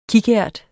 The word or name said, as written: kikært